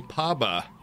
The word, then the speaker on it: Paba: P-A-B-A. – Phonetical? – Seem more P-O-B-A, but... – Poba. – Yeah, in this world, things are pronounced how I want them to be.